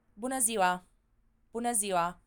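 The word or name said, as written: bună ziua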